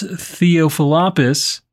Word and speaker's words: Theophilopoulos: Local fire chief Konstantinos Theophilopoulos nailed that pronunciation told state television channel ERT.